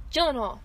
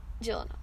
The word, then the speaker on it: Gyllenhaal: Gyllenhaal. – Gyllenhaal.